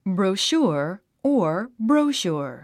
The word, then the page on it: brochure: brouʃúə r; ブローシュァ